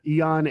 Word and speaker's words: Aeon: It's A-E-O-N. It's a lot of vowels. It's like a bad Words with Friends rack. but Eon, A-E-O-N. – I used the phrase eons ago, but...